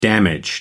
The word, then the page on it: damaged: American English